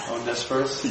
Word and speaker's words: Onesiphorus: Onesiphorus? – Yes, thank you.